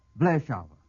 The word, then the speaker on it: Vleeschauwer: V-L-E-E-S-C-H-A-U-W-E-R. Vleischauer.